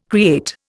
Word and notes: create: Indian

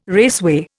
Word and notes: raceway: Indian

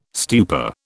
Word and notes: stupa: British English

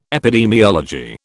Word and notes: epidemiology: British English

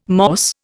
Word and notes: Morse: Indian

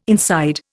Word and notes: inside: Indian

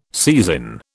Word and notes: seisin: British English

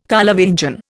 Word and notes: Carlovingian: Indian

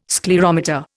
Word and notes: sclerometer: Indian

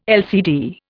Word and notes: LCD: Indian

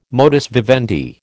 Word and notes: modus vivendi: American English

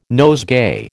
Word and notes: nosegay: American English

